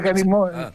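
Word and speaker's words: kalimotxo: Se escribe carimocho, cali con K. – Sí. – Y la CH con TX.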